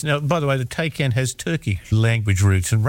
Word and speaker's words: Taycan: I might have said a Tay, but not that, is it? – No, it's a Taycan. – T-A-Y-N.